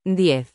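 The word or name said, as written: diez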